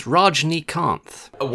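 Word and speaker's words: Rajinikanth: Rajini Kanath. I will edit in a proper Good. – I think the best pronunciation I can do is Rajnikanth.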